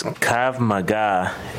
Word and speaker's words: Krav Maga: Maybe for the manager or the police chief, what is Krav Maga law enforcement instruction? – I believe it's pronounced, it's Krav Maga, and it's defensive tactics.